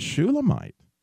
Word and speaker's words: Shulamite: And I come across this interesting information that Solomon in Hebrew is actually pronounced Shelemo, but Shulamite in Hebrew is pronounced Shu Lami. In other words, if you were to say Solomon in the feminine, you would say Shulamite.